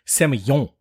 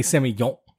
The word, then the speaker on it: Semillon: I did check how to say Semillon, and it's Semillon. – My God, that is so good. – So when you pronounce Semillon, you have to say Semillon. – You have to kind of act like you have a stuffy nose.